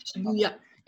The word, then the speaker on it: Shibuya: Is that how you say it? Shibuya? – Shibuya.